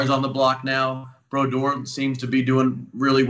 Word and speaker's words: Brodeur: I love that you pronounced Brodeur's name the same way I do, the French way. Yes. We don't let him try and like make it like a regular person's name, Brodeur. No, it's Brodeur.